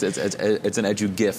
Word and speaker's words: EduGIF: It's an EduGIF for me. It's a hard G. I know the creator says it with a soft G, but it doesn't sound right to me, man. It's G for graphics.